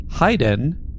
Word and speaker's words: Haydn: I've never met another person with my first name, but I'm a portamento of a Hyman and Franz Joseph uh, Haydn, which is pronounced however my name is pronounced with a high, not a hey.